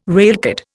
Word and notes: railcard: Indian

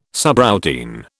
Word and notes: subroutine: British English

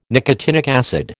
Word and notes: nicotinic acid: American English

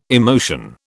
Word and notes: emotion: British English

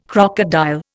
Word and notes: crocodile: Indian

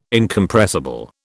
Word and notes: Incompressible: British English

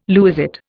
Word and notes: lewisite: Indian

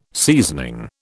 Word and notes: seasoning: British English

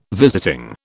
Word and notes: visiting: British English

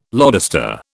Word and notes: lodestar: British English

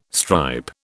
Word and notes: stripe: British English

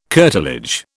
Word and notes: curtilage: British English

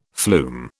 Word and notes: flume: British English

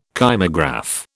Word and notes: kymograph: British English

